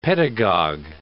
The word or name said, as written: pedagog